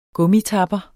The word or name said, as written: gummitapper